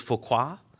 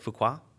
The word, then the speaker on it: Fouquet: So you also had Nicolas Fouquet. That's how you pronounce that, right? Fouquet. – Sure. – Fouquet. I think I'm okay with that one.